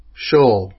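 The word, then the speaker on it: Shaul: The S should really be pronounced with an SH sound. Shh. It should sound like Shoal, like King Solomon actually starts with the SH sound. It's a shh when you read in the Hebrew.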